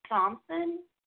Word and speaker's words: Thomasson: Right now, I have Troy Thomasson and.